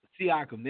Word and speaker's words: Siakam: Yeah, and I'm gonna butcher his name. Siakam. – Siakam. – There you go.